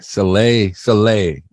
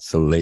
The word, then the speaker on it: Soleil: Soleil, not Soleil. – Soleil, it's got an L on the end of it. – It does have an L on the end of it, yeah. – It's a silent L.